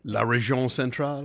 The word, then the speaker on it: La Région Centrale: La Région Centrale, right? – Thank you for saying it right, because I can't say it right.